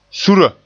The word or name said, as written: sura